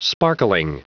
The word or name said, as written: sparkling